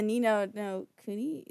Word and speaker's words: Ni no Kuni: Like the Ni no Kuni, is that, did I say that right?